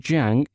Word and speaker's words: Jiang: Hong is H-O-N-G and Jiang is spelled as J-I-A-N-G.